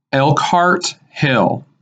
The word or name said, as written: Elkhart Hill